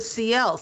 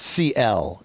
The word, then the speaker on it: Ciel: I thought it was a CL, C-I-E-L. – No, it says, oh, you're right. It's pronounced C-L. It's C-I-E-L.